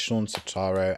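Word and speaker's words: Shawn Setaro: Okay, it's Sean Sotaro, and his name is spelled S-H-A-W-N and S-E-T-A-R-O.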